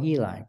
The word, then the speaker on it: Eli: And no big deal, but my first name is pronounced Eli. – Oh, Eli.